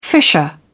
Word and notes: fissure: British English

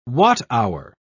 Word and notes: watt-hour: British English

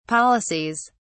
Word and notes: policies: British English

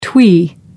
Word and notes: twee: American English

